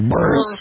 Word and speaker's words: Buress: Do you remember, is that how you pronounce the name of the comedian? Is it Buress or Burris? – Burris. – I think it's Burris. – Burris. – It's Burris.